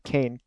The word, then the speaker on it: Kean: It looks like you want to pronounce it Keen, of course, but it is pronounced Kane, even though it's spelled K-E-A-N.